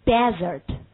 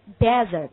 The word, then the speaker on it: desert: 沙漠它就读 desert， desert。你看它重音落在第一音节 desert。